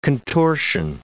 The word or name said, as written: contortion